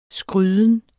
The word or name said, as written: skryden